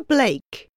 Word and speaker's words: Blake: Monica Blake. – Blake? Can you spell that, please? – B-L-A-K-E.